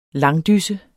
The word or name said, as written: langdysse